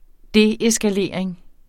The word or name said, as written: deeskalering